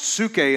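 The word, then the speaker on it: psuche (ψυχή): The soul is pronounced psuche in the Greek, but that's where we get the word psyche, right?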